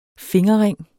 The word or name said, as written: fingerring